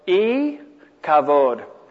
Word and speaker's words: Ichabod: And that's pronounced just with an I. So it's E Kavod, no glory.